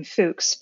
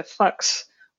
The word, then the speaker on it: Fuchs: Yeah, I love the story of, I call him Fuchs, but I've been told that in order to pronounce this properly, it's something closer to Fuchs, which is fun to say for an English speaker.